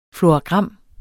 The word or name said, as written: FLORAGRAM